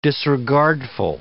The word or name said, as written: disregardful